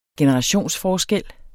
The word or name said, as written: generationsforskel